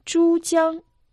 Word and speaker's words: Чжуцзян: Чжуцзян, река Чжуцзян. Первый иероглиф «чу» произносится в первом тоне.